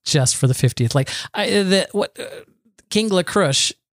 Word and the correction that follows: Kringla: King La Crush, King La Crush. – Kringla, bakery in Epcot.